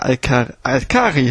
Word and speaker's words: Alkari: Die Alkari, ich muss mir überlegen, wie die heißen. Alkari, ist richtig. Alkari, ja, genau.